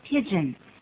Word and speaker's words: pidgin: pigeon English， pigeon 的拼法是 P-I-D-G-I-N， pigeon，可是这又不是鸽子英语啊，鸽子的发音是一样的，拼法是 P-I-G-E-O-N。